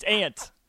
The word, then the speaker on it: aunt: I know there's a U, but it's silent. It's aunt. Auntie. Auntie Ann's, not Auntie Ann's. And she's not an insect, but it's pronounced the same way.